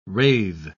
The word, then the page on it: rathe: British English